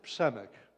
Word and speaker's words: Przemek: Okay, so my name is Przemek. Bless you, you just all sneezed. That's okay. So let me teach you. The first sound is really simple.